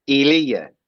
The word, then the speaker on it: Elia: Elia.